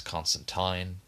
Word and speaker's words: Constantine: or as it's actually pronounced, Constantine, but I refuse to say that because it just sounds ridiculous.